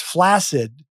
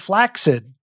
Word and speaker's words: flaccid: And I've always heard the word pronounced flaccid, which is acceptable. – Sure. – And he said flaccid, and I got upset. – That's insane. – And it's accepted. There are two pronunciations.